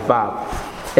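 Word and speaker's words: Vav (ו): They pronounce it as Vav. And the Vav, V-A-V, is what?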